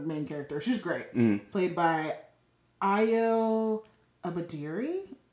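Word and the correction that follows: Edebiri: Is that how you say it? – I've never... – Ayo... – I don't think I've ever heard of this person. – She's so good. Yeah, Ayo Edabiri.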